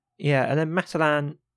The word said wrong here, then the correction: Maitland: who is not from shop, but I keep getting how you pronounce his name. – Maitland. – Maitland. – yeah, Maitland.